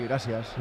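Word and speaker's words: gràcies: Pero va a hacer un homenaje, por cierto, lleva una camiseta que pone detrás el gracias en catalán, gracias. Y, bueno, ¿bien pronunciado? – Sí, gracias. – Gracias.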